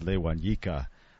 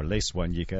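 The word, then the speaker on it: Les Wanyika: This is Lewanyika, or Leswanyika, as they pronounce it in Kenya, from the CD Amigo.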